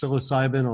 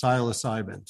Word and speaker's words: psilocybin: Uh, it actually depends on what side of the Atlantic. So on your side, it's psilocybin. On the side that we're in at London, it's psilocybin.